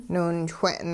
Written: niwinchwe'n

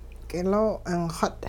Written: k'iloo-'ingxut

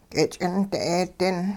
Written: k'ich'int 'eedin

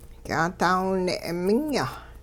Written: ky'aadaawine' minya

